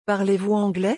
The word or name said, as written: parlez vous anglais